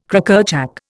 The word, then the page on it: Crackerjack: Indian